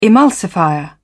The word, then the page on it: emulsifier: British English